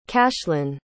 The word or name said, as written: cashlin